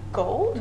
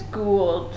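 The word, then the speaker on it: Goold: So, this was directed by Rupert Gold? – Gold. – How do you think you pronounce it when there's two O's? – Oh, there's two O's? – Yep. – Whoops. I think it's Gould.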